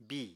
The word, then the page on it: bìi: Apatani